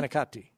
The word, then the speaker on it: manicotti: Manicotti. – Manawati? – Manicotti is how the American pronunciation is. You say manicotti.